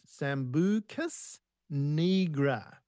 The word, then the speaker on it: Sambucus nigra: One plant today, Sambucus nigra, pronounced Sambucus nigra, common name, the elderberry.